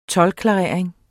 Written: toldklarering

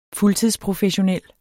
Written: fuldtidsprofessionel

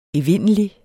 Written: evindelig

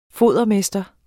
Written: fodermester

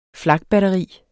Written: flakbatteri

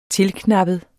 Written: tilknappet

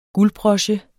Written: guldbroche

